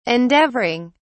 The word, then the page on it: endeavouring: British English